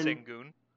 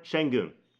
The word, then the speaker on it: Şengün: But we're going Alperen Şengün. – Alperen Şengün. It's a sh sound. – Şengün. That's there what I go. Said. I'll edit it and put that audio. – Appreciate it. Alperen Şengün, they are in desperate need of a center.